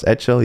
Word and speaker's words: Eccel: And he also asked a question in one of the previous episodes, and I mispronounced his name to Thomas Excel. So Thomas Etchell. Now I know it's Thomas Etchell.